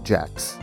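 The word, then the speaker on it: Jaques: We start way back in the 1950s with a man named Dr. Elliot Jax, spelled French, pronounced American.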